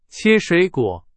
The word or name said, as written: 切